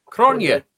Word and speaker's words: Cronje: I don't know if you've seen this one from this tweet from AP, and I'm going to say his name wrong, AP Cronje, C-R-O-N-J-E, from South Africa. – Cronje. – Cronje, thank you.